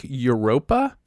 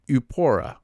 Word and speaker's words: Eupora: From looking at the band name, you would think that it was Rock Europa, but it's Rock Yupora, E-U-P-O-R-A, Yupora. I have to assume that I'm saying that incorrectly, but it's not Europa, which is what I want it to be, and I think that's the trick.